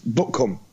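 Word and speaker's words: Butcombe: That's how they spell it, but it's pronounced Buttcombe.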